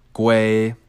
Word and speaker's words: gwei: That's spelled G-W-E-I, pronounced GWEI, which itself is a denomination of ETH.